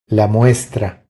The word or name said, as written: La muestra